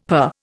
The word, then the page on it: purr: Indian